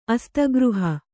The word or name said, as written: ಅಸ್ತಗೃಹ